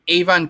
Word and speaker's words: Aven: But the game for gold stuff, you're getting Avon Colony, I believe is how you pronounce that, A-V-E-N.